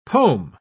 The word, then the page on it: pome: British English